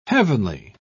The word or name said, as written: heavenly